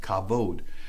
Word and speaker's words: kavod: That's K-A-B-O-W, but it's pronounced kavod, which is defined as weight or heaviness.